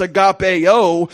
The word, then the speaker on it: agapeo: There's another couple letters added to it, and it's pronounced agapeo, and it's an affection.